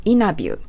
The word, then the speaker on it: interview: カナダ英語、アメリカ英語では、インタビューの他を飛ばして発音します。 interview。